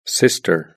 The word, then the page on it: sister: British English